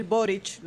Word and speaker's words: Boric: lo pronuncio así porque Consuelo ha insistido, no. Consuelo dice que se pronuncia CH, no con C, Boric. Sí, porque me llamaron la atención a mí porque yo dije Boric.